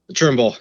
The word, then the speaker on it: Trimble: Well, that's been Kyle Thimble. Thank you so much. Did I say it right? – Trimble. – Trimble. Damn it.